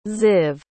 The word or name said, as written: ziv